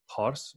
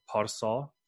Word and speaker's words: Pars: In the older Persian, it would have been pronounced Pars or Parsa.